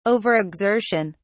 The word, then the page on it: overexertion: British English